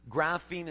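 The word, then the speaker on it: graphein: Graphene is spelled G-R-A-P-H, like the word graph, E-I-N. It means to write.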